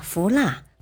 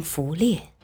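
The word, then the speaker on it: 伏腊: 可是萧贵不学无术，在中书侍郎严挺之面前，公然把“ 福蜡” 读成“ 福烈”。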